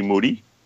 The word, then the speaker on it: Moody: So it was him and his co-host, Gerald Moody, G Moody, rhymes with duty, and his two producers.